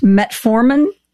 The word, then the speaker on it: metformin: I've got to ask you, you know, one of the things that I've read about is the use of, I don't use metformin.